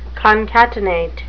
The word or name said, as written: concatenate